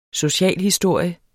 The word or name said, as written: socialhistorie